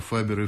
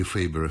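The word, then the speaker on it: Faber: Эллиот остается в Англии, женится, преподает латинский и французский в различных британских школах, а позже становится сотрудником известного издательства «Фабер и Фабер», или «Фейбер и Фейбер», как это произносится по-английски, прославившегося своими публикациями поэтов XX века.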